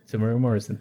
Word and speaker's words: Temuera Morrison: Temuera Morrison.